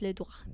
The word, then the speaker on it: doigt: And in French it's le doigt.